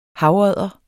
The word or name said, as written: havodder